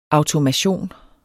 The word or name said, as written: automation